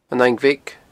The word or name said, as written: An Fhaing Bheag